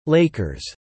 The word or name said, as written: Lakers